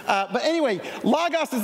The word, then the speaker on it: logos: They had this Greek word called logos or logos, depending on who taught you Greek. Greg, how do you say it? – Logos. – Logos. See, I learned from Harvey Floyd that it's logos. I'm just saying, it can go either way.